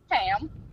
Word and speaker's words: Pam: And then we got some Pam cooking spray. – Pan. – Pan. It's like calm. – No, the N is silent. – Pam.